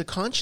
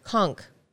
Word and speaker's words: conch: Is it a conch? Is that how you pronounce it? – Yes. – If you put it up against your ear, you can hear it breathing heavily. – At least you pronounced it properly. I was looking up videos on how to properly clean a conch because we had found one. – Yeah. – And she was pronouncing, like, the H wasn't there.